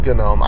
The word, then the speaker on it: GNOME: I guess I got a proper pronunciation's GNOME. I can't stand pronouncing that G.